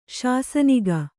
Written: ಶಾಸನಿಕ

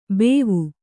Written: ಬೇವು

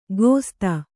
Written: ಗೋಸ್ತ

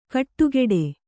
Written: ಗುಡಿಗುಡಿ

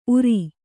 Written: ಊರ್

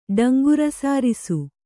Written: ಡಂಗುರಸಾರಿಸು

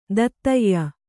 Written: ದೂತ್ಯ

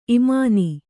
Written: ಇಮಾನಿ

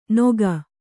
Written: ನೊಗ

